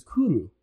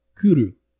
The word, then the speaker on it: Cwrw: The Welsh word for beer is cwrw, and it's spelled C-W-R-W. – Say it one more time. – Cwrw. – Cwrw. – C-W-R-W. It is a word that in English does not have a vowel.